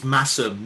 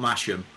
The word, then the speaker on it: Masham: It's Massam, not Masham. – Oh, okay. – It's a silent H.